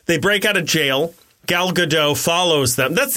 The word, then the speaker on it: Gal Gadot: You know how they fought like Gal Gadot? – It's Godot. – I don't think it is. – I've heard it said. I mean, it could be. I could be wrong. – The T is pronounced.